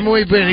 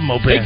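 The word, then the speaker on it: muy bien: Instead of Moe Bien, he goes Mo Ben. – Mo Ben. – And you know what? And I love Mo Ben.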